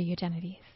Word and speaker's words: Eugenides: My guest today is none other than Jeffrey Eugenides.